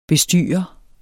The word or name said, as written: bestyrer